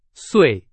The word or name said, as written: 岁